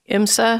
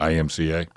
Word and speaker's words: IMCA: and they're going to have IMSA. Is that how you say it? – IMCA.